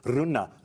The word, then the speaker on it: Rune: How do you pronounce his name again? And she was giving us all the two. Rune, Rune, And now look where he is.